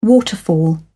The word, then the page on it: waterfall: British English